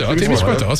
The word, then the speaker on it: Témiscouata: Tu dis Témiscouata, c'est ça? – Témiscouata, c'est bon.